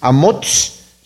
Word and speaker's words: Amots: Vimos que este Amós no es el profeta Amós, porque se pronuncia y se escribe diferente en hebreo, es Amots.